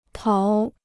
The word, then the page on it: 逃: táo